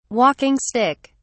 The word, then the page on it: walking stick: British English